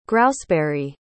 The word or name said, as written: grouseberry